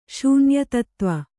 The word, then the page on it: ಶೂನ್ಯತತ್ತ್ವ: Kannada